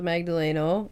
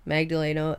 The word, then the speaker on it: Magdaleno: All right, so my name is Samantha Magdaleno, Samantha, S-A-M-A-N-T-H-A, Magdaleno, M-A-G-D-A-L-E-N-O, running for state representative in District 6.